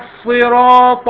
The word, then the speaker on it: الصراط: اهدنا الصراط المستقيم. – اهدنا الصراط المستقيم. – اهدنا الصراط المستقيم. – اهدنا الصراط المستقيم. – طبعا هنا الأخطاء كثيرة. الصاد بيخلوها سين والطاء بيخلوها تاء